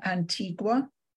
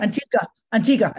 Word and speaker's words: Antigua: And would you say that the majority of your sales is coming from Antigua? – No. – Antigua. I said it wrong again.